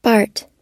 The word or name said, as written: bart